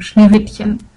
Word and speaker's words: Schneewittchen: And as I said, I'm from Germany, and I just had to let out my inner Hermione and tell me that it's actually pronounced Schneewittchen. The full name would be Schneewittchen und die Sieben Zwerge.